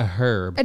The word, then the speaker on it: herb: C, a herb. – An herb. – An herb. – Herb. Herb. – Herb. Herb. Herb. – The H is silent.